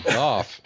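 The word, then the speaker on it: Gough: And I don't even want to try Al's last name because I see that O-U-G-H together. And I'm like, how the fuck do you pronounce that? – But, Goff, baby. Goff. Off with a G.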